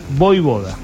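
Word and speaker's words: Vojvoda: Es que la J se pronuncia como I. Es como Djokovic. – Voivoda, es fácil. – Es como Djokovic. Djokovic es con J en el medio. Bueno, Voivoda es lo mismo.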